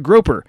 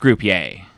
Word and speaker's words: Groper: Joining us to discuss these allegations is senior Supreme Court correspondent Cordell Nutbrock and Senator Howard Groper, Republican from Mississippi. – It's pronounced Groupier.